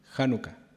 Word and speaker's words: Hanukkah: También hace referencia a un hecho simbólico muy importante de la religiosidad judía, que tiene que ver con la fiesta de Hanukkah o Hanukkah, de acuerdo a cómo se pronuncia, no sé, Elia, vos corregime. Hanukkah, perfecto.